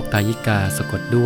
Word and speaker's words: ทายิกา: ทายิกา